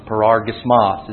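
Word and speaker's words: parorgismos: Greek words often have the emphasis on the very final syllable, so it's kind of awkward to our English tongues. Paragismos is how the word's pronounced.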